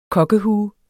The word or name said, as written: KOKKEHUEN